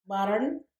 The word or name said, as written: barrern